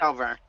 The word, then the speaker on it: Alverne: Alverne. – Oh, there you go. – It's Alverne.